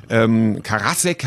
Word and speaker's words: Karasek: Karasek, habe ich gelernt, wird es ausgesprochen von Stuttgart Barre.